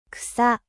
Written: herbe

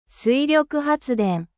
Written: energie hydraulique

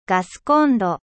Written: gaziniere

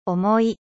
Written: lourd

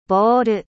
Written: bol

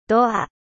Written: porte

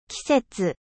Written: 季節

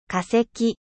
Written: fossile

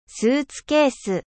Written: スーツケース

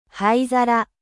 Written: cendrier

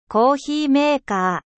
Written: cafetiere

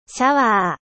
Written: douche

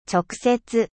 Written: direct

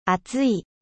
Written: chaud

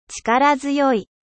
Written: 力強い

